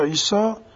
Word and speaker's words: عیسی: کلمه عیسی که ما میگیم تو قرآن همین تعبیرات و آتینا عیسی ابن مریم در حقیقت آمده جزء واژگانی است که ما با تلفظ عربی اون را تلفظ میکنیم، منتها کلمه دخیل اصطلاحاً بهش میگن، یعنی داخل شده در مجموعه کلمات عربی. در لغت سریانی این کلمه عیسی اولش ایشو بوده که همزش افتاده، عرشبت خدمتون می که و یشو یا یشوعا تبدیل شده.